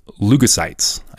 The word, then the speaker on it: leukocytes: So in this immune system we have, there's white blood cells called leukocytes. I think that's how you pronounce it. Is that how you pronounce it? – Leukocytes? Okay. So these leukocytes, there's two groups of these white blood cells.